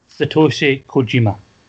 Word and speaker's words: Satoshi Kojima: I'm going to need your help pronouncing that name. – Satoshi Kojima. – Well done.